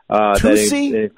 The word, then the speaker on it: tusi: Tusi? – Yep, like 2C, right? – T-W-O-C? – It's spelled T-U-I-S-I, but it's pronounced like 2C.